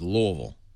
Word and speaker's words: Louisville: And he said, now, if you're going to use this bat, you got to say it right. It's Louisville.